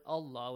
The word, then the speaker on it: Allah: The lam of the word Allah will be pronounced heavily.